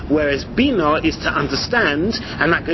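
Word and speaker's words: bino (בינה): Rashi says, the word bino, with the accent on the nun, is a noun.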